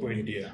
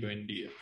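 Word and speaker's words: Buendía: I don't know how you say his name. – Buendia. Emi Buendia.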